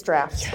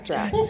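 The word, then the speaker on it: Draught: Well, and what we're about to talk about, I'm gonna say it wrong, the draft of peace. – It's draft. – Yes, okay. Say it wrong. – Yeah, draft.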